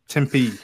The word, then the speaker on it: Tempe: Tempe, Arizona.